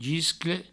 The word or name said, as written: giscle